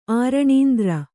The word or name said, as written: ಆರಣೀಂದ್ರ